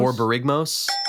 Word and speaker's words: Borborygmos: Oh my gosh, Borborigmos, right? Is that close? – Borborygmos, I think maybe might be the best way to pronounce it.